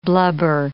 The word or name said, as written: blubber